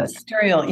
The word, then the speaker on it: Mysterial: Is it Mysterial? Is that how you say it? – Mysterial.